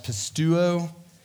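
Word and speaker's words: pistuo (πιστεύω): If you care about such things, it's pronounced pistuo.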